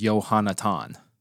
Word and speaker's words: Yohanatan: Which is actually different than Jonathan, which is Yohanatan, and they mean different things.